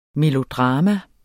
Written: melodrama

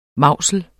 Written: mausel

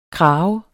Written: krave

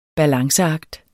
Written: balanceakt

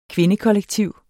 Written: kvindekollektiv

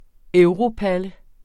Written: europalle

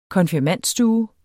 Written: konfirmandstue

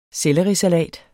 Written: SELLERISALATEN

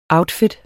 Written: outfit

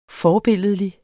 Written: forbilledlig

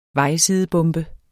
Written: vejsidebombe

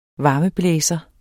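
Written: varmeblæser

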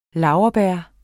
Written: laurbær

